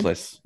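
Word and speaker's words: Zempilas: How do you say his Zemplis. – Name? – Zemplis. Yeah. Oh, Zemplis.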